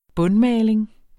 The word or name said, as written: bundmaling